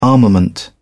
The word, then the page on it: armament: British English